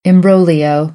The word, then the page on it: imbroglio: American English; oʊ